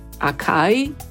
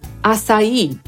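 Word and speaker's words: açaí: Wyobrażałam sobie, że może się je czyta acai, a to jednak acai. Taka jest poprawna angielska wymowa.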